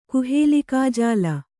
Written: ಕುಹೇಲಿಕಾಜಾಲ

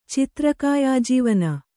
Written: ಚಿತ್ರಕಾಯಾಜೀವನ

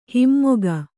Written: ಹಮ್ಮುಗೆ

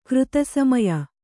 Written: ಕೃತಸಮಯ

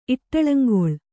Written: ಇಟ್ಟಳಂಗೊಳ್

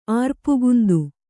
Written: ಆರ್ಪುಗುಂದು

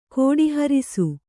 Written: ಕೋಟಿಹೊರಿಸು

